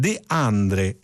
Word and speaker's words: De André: Tutto questo da Achille Lauro in Rolls Royce, sì, ma come De André, anzi, e qui ci aiuterebbe più la grafia della pronuncia, come De Andre.